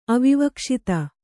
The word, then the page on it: ಅವಿವಕ್ಷಿತ: kannada